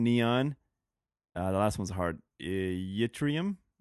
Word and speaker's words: Yttrium: It's Yttrium. – Oh, yeah, that's yttrium.